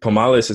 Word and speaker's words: Pomales: Yeah, before we pressed record, I was like, POMALES is how you say your last name, right? You're like, yeah, perfect.